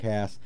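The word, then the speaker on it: Hass: Does Mark Haas, and by the way, it's Haas. Some people say Haas, and that leads people to spell it with two A's. It is not spelled two A's. It's H-A-S-S. And people say Haas, I don't know, because there's like a big trucking company of that name.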